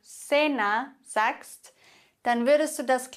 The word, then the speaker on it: cena: Das heißt, C vor E und I würde man aussprechen Cielo, Cielo, der Himmel. Oder Cena, das Abendessen, Cena.